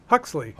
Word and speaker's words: Huxley: Huxley.